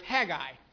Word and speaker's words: Haggai: I pronounce it Haggai. I've been doing that my entire life, but I was told there's no E in it. So it's Haggai.